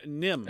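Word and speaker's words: NIMH: I always wondered as well about the pronunciation of this. The Secret of Nim? Nim? Nim? – Yeah, Nim, and I believe it is.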